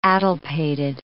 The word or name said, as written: addlepated